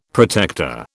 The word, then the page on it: protector: British English